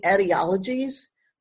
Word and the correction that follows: etiologies: but this word, which means an origin story, should be pronounced etiologies. The problem is I first learned the word by reading it, and I never heard it spoken until like years later.